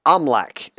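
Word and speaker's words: Oimelc: Omlak is how it's pronounced.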